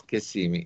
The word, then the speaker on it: Kissimmee: Bueno, vamos a ver, claro, Kissimmee, así es como yo lo conozco. – Es Kissimmee. Es que en Florida hay nombres bien curiosos.